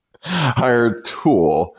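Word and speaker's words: Hiretual: Is it pronounced Hire Tool or yes. Hire Tool? – Hire Tool. – Tool. They just can't spell that shit right.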